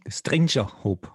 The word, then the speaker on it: Stranger Hope: Strang, Stranger Hope. Das ist wichtig, die Betonung Strange, ist wichtig. Stranger.